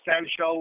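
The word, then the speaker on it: San Shao (散手): That must have helped you in the San Shao. Is that how you pronounce it? – Yes. San Shao or San Da, it's the art of Chinese style full contact fighting.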